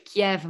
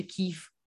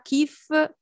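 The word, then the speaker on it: Kif (Київ): che non si dice Kiev, ma si dice Kif. Mm-mm. – Sì, che poi tempo fa mi spiegasti che si può dire sia Kif che Kiv a seconda della, della provenienza.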